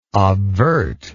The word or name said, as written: obvert